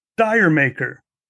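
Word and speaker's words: D'yer Mak'er: And I just remember on the album, it had that little brown circular sticker on it declaring includes Jamaica, which most people in the U.S. pronounced as Dire Maker, not being familiar with the Jamaican joke from England.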